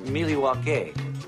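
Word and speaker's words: Milwaukee: Actually, it's pronounced Miliwake, which is Algonquin for the good land.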